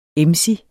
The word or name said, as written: emsig